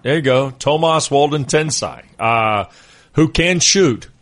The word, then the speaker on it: Tomas: And by the way, I got to the bottom of the pronunciation business with Thomas, the artist formerly known as Thomas Wolde Tensai. He says he prefers to go by Tomas.